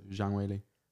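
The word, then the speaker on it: Zhang Weili: Zhang Wei Li. So although it says Wei Li Zhang, with Chinese people, you say their last name first. – Is it? Sick. – So Zhang Wei Li.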